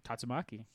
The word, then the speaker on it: Tatsumaki: Tatsumaki versus Jean Grey. Did I say that right? – Tatsumaki.